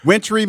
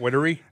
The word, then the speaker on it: wintry: Somebody hit me up on Twitter last night talking about, see, it's pronounced wintry. Just say wintry. – Not wintery? – And I was about to say, shut up!